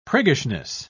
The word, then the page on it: priggishness: British English